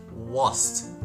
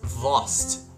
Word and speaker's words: Wurst: Sausage, in fact, is in German, Wurst. Okay? No, not Wurst. – Uh-huh. – I had to search for the correct pronunciation. It's Wurst. Okay?